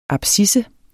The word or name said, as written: abscisse